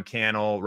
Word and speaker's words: Cannell: Recently I started saying, my name is Sean Cannell, rhymes with YouTube channel, for a couple reasons, because everyone says Cannell.